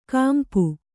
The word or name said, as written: ಕಂಪ